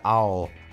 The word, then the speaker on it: I'll: A pronúncia de I will é I'll. I'll.